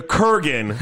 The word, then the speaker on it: Kurgan: He was the Kurgan from the other movie we watched.